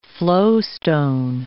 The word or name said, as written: flowstone